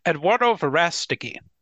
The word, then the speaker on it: Eduardo Verástegui: And I don't want to mispronounce his name, but I believe it's Eduardo Verástegui. – Eduardo Verástegui.